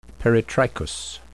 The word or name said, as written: peritrichous